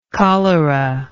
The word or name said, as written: choleraic